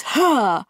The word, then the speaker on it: Hare: His name is Hare, which rhymes with chair.